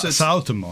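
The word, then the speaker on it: Suotamo: Jonas Jonas Sutomo. I don't know how to pronounce his name. Sutomo. – Jonas Sautomo. – Sautomo.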